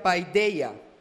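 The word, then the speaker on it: paideia (παιδεία): La palabra disciplina en el griego quiere decir o se pronuncia paideia y tiene un significado de mayor peso.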